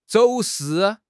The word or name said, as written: 走私